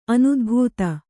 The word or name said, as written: ಅನುದ್ಭೂತ